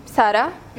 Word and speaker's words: Sarra: It's all, I write it differently, but it's Sarra or Sarra here. They pronounce it Sarra, so it's, okay. Yeah. – What do you prefer, Sarra or Sarra? – It depends. I mean, I go by Sarra, so that's the easy way.